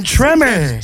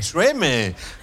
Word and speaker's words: Tremé: Born in Tremé. – Tremé. That is more nice. – Hey! – French pronunciation.